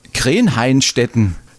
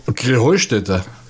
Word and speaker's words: Krähenheustätte: Ja, ihr saget immer Krenheinstetten. Und dabei heißt es doch Krähenheustätte, oder wie? – Krähenheustätte. – Ja, genau, und das ist die Betonung auf dem Heu.